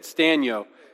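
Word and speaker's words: Sztanyo: Stanyo. It's got a Z in it that just looks like it should be more prominent than it is. It's S-Z-T-A-N-Y-O.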